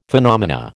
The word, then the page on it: phenomena: American English